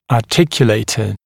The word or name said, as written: articulator